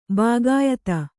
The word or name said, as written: ಭೋಗ್ಯಾಧಿ